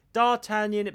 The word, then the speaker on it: D'Artagnan: D'Artagnan, it begins with D, one syllable.